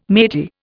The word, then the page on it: matey: Indian